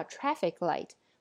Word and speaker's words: traffic light: 还有复合名词，上次说到 traffic light，复合名词的重音一定要放在前边。